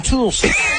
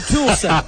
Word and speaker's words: Tulsa: You're both using two syllables, but he's going Tulsa. It's just like I'm gonna go out to my tool shed. I'm gonna go Tulsa.